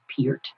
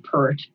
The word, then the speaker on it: Peart: And yes, you do pronounce it Peart. I've heard pert, but no, he always said Peart.